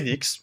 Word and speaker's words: Knicks: Knicks, comment on prononce bah, C'est Knicks, c'est Knicks. – On, alors, prononce pas le premier cas quoi.